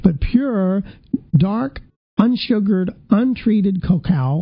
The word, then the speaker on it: cacao: According to researchers at Cornell University, cocoa, or cacao is actually how they say it, is a major source of cancer-fighting antioxidants.